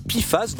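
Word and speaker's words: PFAS: Espèce de pifas, donc pourrait-on dire amicalement puisque c'est de cette façon que se prononce l'acronyme précité.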